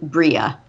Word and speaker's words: Breha: But I think all the Breha, Mara and I both say Breha, but I can tell you, loyal listeners, that in the audiobook they pronounce Leia's mother's name Bria. – Bria. – But Bria, like Bria, but it's spelled Breha. So all these years I pronounced it Brea or Breha.